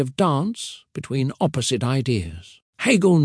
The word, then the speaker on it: Hegel: Georg Wilhelm Friedrich Hegel, pronounced Hegel, was a German philosopher who lived from 1770 to 1831.